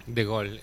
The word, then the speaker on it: Gaulle: Y así, después de varios intentos y conflictos con las autoridades, Naseri terminó en el aeropuerto Charles de Gaulle. – Charles de Gaulle. – ¿Cómo se dice? – De Gol. – ¿De Gol? Bueno, perdón, para no decirlo. Yo estaba diciendo en argentino. – Sí, es francés.